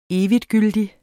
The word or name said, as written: eviggyldig